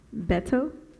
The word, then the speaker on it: Beto: Los Bros Hernandez, Beto and Jaime. – Nice. – I probably mispronounced his name. – Beto? Oh, you got it.